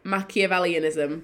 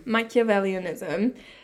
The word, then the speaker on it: Machiavellianism: Machiavellianism. – Machiavellianism.